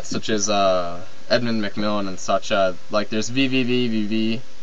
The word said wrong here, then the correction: VVVVVV: No, did I say that right? VVVVVV. There we go. – Wait, you have to count out whenever you say it.